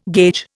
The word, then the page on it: gauge: Indian